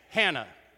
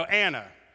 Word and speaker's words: Anna: Like, you mean Hannah? No, the H is silent and actually not even written. Her name is Anna.